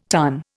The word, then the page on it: tonne: Indian